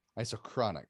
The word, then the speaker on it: isochronic: Isotronic. Did I say it right? – Isochronic.